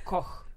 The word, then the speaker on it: Koch: Okay, tell me the correct way to say it. – Koch.